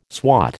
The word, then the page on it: swat: American English